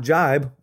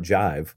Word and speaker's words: jibe: But the point is, that whole concept does not jibe, which I only recently learned is the correct pronunciation. It's not jive.